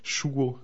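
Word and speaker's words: ŝuo: Ah oui, chuo. Alors chuo, c'est peut-être difficile, ça veut dire la chaussure. Hein, c'est des choses en anglais. Et donc, ça s'écrit avec un S accent circonflexe en espéranto.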